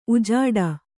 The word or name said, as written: ಉಜಾಡು